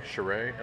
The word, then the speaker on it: Shirai: Shirai.